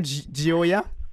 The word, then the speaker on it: Gioia: Est-ce que je le prononce bien, Gioia? – Oui, bonjour. Oui, tout à fait. Vous le prononcez très bien. Gioia, c'est le nom italien, le mot italien pour la joie.